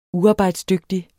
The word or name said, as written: uarbejdsdygtig